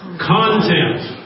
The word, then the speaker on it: content: That's the on the accent on the first syllable. Content. Okay, thank you. Sorry, there's a little confusion there, but same word, different pronunciation.